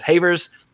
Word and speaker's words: Havers: And I asked about the pronunciation of Havers, rhymes with Shavers.